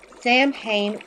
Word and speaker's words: Samhain: Sam Hain Prop Works.